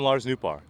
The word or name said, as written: Lars Nootbaar